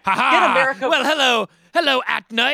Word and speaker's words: Aetna: I have a cure, Atnia. It's me, Atna. How do you say that? Atna, Aetna, Edna.